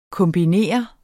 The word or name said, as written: kombinere